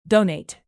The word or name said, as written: donate